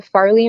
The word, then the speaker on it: Farlee: One is the Farlee and Friends, and it's spelled F A R L E E, Farlee and Friends, instead of the EY.